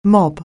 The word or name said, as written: mob